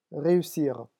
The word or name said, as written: reussir